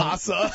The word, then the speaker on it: Hossa: Hossa. – Marion Hossa. – I pronounced it wrong. – Hossa. Hossa. I didn't play last time.